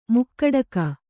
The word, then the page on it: ಮುಕುಡಕ: kannada